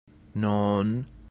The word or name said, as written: non